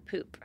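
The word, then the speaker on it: Pupp: It was supposedly set at the Grand Hotel Poop. – Mm-hmm. – which is exactly pronounced like that.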